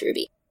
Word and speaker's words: RWBY: The most current fandom that I'm super duper into is Ruby, and it's spelled R-W-B-Y, but because of the lore of that world, it's pronounced Ruby.